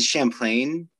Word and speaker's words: Champlain: But now you're at, I always get nervous, I'm going to pronounce it wrong, but I think is it Champlain? – Mm-hmm. – Okay. So in New England, mm-hmm. can you remind all the listeners, where is it located? – Sure. So now I'm associate professor at Champlain College,